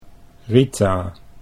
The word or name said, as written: Rita